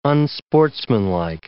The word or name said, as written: unsportsmanlike